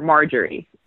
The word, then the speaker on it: Marjorie: So it's actually short for Marjorie. – Um, Marjorie. Marjorie spelled with a J.